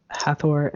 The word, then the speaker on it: Hathor: Hathor and Shekhmet, if that's the correct pronunciation. I'm waiting for him to murder me later and be like, you pronounced that wrong. – You did it right.